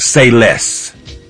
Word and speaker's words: Seiles: It's called Say Less. – Perfect. – And that's spelled S-E-I-L-E-S. It's Low Key, L-O-K-E-E.